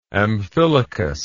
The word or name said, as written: Amphilochus